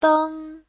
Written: deng1

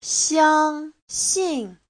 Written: xiang1xin4

